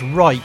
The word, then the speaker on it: Rike: And I made sure I pronounced it correctly. So it's Rike, R-I-K-E. And that's how the guys like it pronounced.